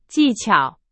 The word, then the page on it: 技巧: Chinese